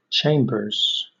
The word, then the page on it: chambers: British English